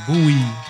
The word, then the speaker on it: Bowie: O no, como dicen en algunos lugares estados de Estados Unidos, David Bowie. Lo pronuncian así, Bowie.